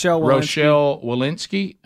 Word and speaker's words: Rochelle Walensky: Rochelle Walensky.